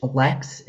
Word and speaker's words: Leks: Lex, it's spelled L-E-K-S, Vucko, V-U-C-K-O.